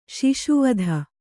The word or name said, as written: ಶಾಶ್ವತ